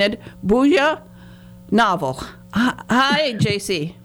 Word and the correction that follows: Bruja: Bruja. – Bruja. I knew it was Bruja, but, you know, they... I get people who then they'll pronounce it with the J, and I get so nervous.